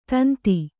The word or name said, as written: ತಂತಿ